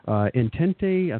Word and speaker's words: Entente: Entente, I think that's the right pronunciation, E-N-T-E-N-T-E,